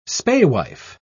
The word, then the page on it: spaewife: British English